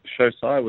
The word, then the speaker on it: Shosai: because it's called Shosai. Is that, have I got the pronunciation right? It's detail in Japanese. Is that right? – Yeah, so it's Shosai,